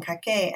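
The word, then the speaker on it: kakke: or in the Japanese pronunciation Kake, and beriberi as a creative act rather than one of discovery.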